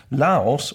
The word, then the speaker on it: Laos: Laos, of officieel Democratische Volksrepubliek... – Laos, gaat klemtoon, dit ging even verkeerd met de klemtoon. Laos, of officieel Democratische Volksrepubliek Laos...